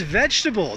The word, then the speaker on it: vegetable: This one's a little bit like comfortable. A lot of my students pronounce it vegetable. Vegetables. I eat my fruits and vegetables. Way too many syllables there. Again, this one's three syllables like comfortable.